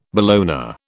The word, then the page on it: bologna: British English